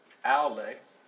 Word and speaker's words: aule: And that is, it's spelled like this, A-U-L-E-S, and it's pronounced aule, like aule, kind of like that.